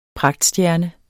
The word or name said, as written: pragtstjerne